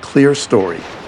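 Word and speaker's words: Clerestory: Jules Reedy has a new record out, and I made sure to look up the pronunciation of this one. The album is called Clear Story, C-L-E-R-E-S-T-O-R-Y. I would have almost certainly gotten that one wrong, but apparently it refers to the window at the top of a building.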